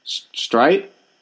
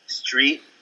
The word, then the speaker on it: Streit: You kept saying his name wrong. Say it. – Streit? – Yeah. And I said, are you talking about Mark Streit? And you said, Street, Scrum, Screet.